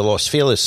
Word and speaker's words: Los Feliz: That's the Dresden Room, which is on Vermont in the Los Feliz section of Los Angeles. – You pronounced it right.